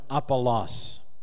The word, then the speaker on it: Apollos: In Greek, that would be pronounced Apollos.